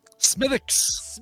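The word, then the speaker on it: Smithwick's: Smithix. You don't pronounce that W.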